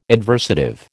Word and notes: adversative: American English

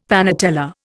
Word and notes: panatella: Indian

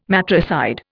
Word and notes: matricide: Indian

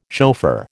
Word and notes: chauffeur: American English

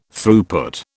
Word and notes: Throughput: British English

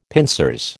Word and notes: pincers: American English

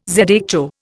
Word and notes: zho: Indian

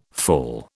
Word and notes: fall: British English

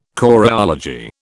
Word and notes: choreology: British English

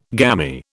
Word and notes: gammy: British English

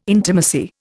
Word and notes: intimacy: Indian

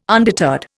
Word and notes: undeterred: Indian

